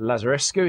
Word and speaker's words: Lazarescu: The Death of Mr. Lazarescu.